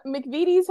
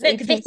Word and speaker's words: McVitie's: Girls, I have to tell you, so I came to Britain for the first time a couple decades ago, and these McVitie's has been making hobnobs, I think, well, at least the box says, since McVitie's. – 1839. – It's pronounced McVitie's. – McVitie's, apologies.